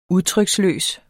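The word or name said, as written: UDTRYKSLØST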